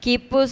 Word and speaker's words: Khipu: Kipu se escribe K-H-I-P-U.